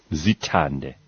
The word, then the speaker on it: Zithande: Z-I-T-H-A-N-D-E, right? – Yes. – Zitande.